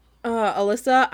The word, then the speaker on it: Elyssa: Her mother, Alyssa, I don't know why I kept, when I was reading it, they spell it E-L-Y-S-S-A. And I kept thinking it was Elisa, but okay, then my dyslexic brain was also reading it as Elsa.